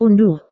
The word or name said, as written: unduh